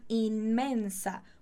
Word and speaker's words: inmensa: Então, llevar una inmensa. Muito cuidado com essa pronúncia. Inmensa. Inmensa.